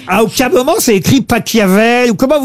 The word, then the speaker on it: Pachelbel: Catelloni, c'est Pachelbel. – S'il est allemand, ça se dit Pachelbel.